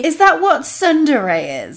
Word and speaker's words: Tsundere: Tsundere